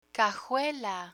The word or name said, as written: cajuela